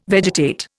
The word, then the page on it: vegetate: Indian